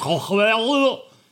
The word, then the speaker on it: Cthulhu: By the way, you can pronounce it however you want. We said Cthulhu. It's honestly supposed to be pronounced Cthulhu, so don't come at me if you say Cthulhu or whatever it is you say.